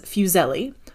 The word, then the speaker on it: Fuseli: Google says Fuseli. I was saying Fuseli in my head. It's one of those. I'm going to say Fuseli, err on that side of, you know, things, but Henry Fuseli.